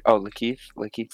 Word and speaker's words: Lakeith: Lateef, if I'm pronouncing his, Lakeith Stanfield. Lakeith. I loved him, and Sorry to Bother You, it's probably one of my favorite movies.